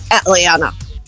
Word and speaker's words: Atlanta: Então, um T depois de N na sílaba fraca, o americano geralmente não vai pronunciar. Então, da mesma forma que o americano fala 20, ele vai dizer Atlanta.